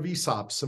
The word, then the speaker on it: ESOPs: We have worker cooperatives in Australia, if I understand correctly, everything that, that offers employee equity is under the rubric of ESOPs. Am I saying that right? – Yeah, that's correct.